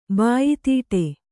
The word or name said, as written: ಬಾಯಿತೀಟೆ